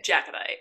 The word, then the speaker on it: Jacobite: Okay, so let me preface this with I know absolutely nothing about the Outlander show, and I actually know nothing about the Jacobite or Jacobite? How did you say it? Jacobite. Jacobite Rising. There you go.